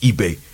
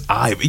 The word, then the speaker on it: Ibe: Because, that gets on my nerves, because it's a Nigerian last name, and so it's supposed to be pronounced eBay. – And everybody says Ibe.